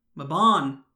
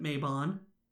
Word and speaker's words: Mabon: Following this is the autumn equinox, Mabon, although some will pronounce it Mabon.